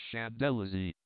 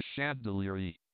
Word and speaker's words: Champs-Élysées: which I don't know why is called the Champs-Élysées, or Elysian Fields, in French. Others, I have heard, call it the Chandelierie, but mine I know to be the correct pronunciation.